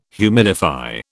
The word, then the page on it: humidify: British English